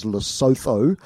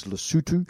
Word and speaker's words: Lesotho: Lesotho is a wonderful place, commonly mispronounced as Lesotho. – Yeah, right, because it's spelled, what, L-E-S-O-T-H-U. – T-H-O. – T-H-O, sorry. – Yeah, so right. if you look at it. – Yeah, yeah. – But the pronunciation is Lesotho.